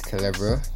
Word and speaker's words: Kelevro: But the name that I go by is Kelevro. K-E-L-E-V-R-O.